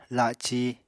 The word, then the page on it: la7ci: Apatani